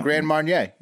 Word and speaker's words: Grand Marnier: What you call it? – Grand Marnier. Yeah. – Oh, you said it right. – That's not what you said. – You didn't say that the first time. – Grand Marnier. – You missed an R, I think.